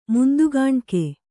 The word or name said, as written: ಮುಂದುಗಾಣ್ಕೆ